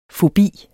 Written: fobi

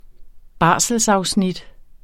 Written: barselsafsnit

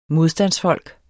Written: MODSTANDSFOLK

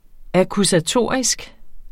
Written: akkusatorisk

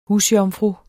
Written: husjomfru

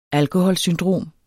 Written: alkoholsyndrom

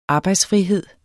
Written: arbejdsfrihed

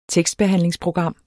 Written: tekstbehandlingsprogram

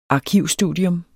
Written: arkivstudium

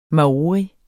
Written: maori

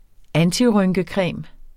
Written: antirynkecreme

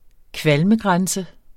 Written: kvalmegrænse

